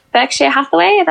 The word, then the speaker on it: Berkshire Hathaway: I bought Berkshire Hathaway.